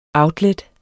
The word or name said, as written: outlet